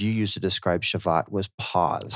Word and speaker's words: Shabbat: It's a B when you write in English, but it's more of a V pronunciation. – Mm, okay. So Shabbat.